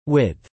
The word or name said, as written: width